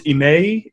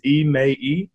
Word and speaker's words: Ime: Just, you know, so everybody in the room knows and all out in the interwebs, my first name is pronounced Ime. It's three syllables, Ime.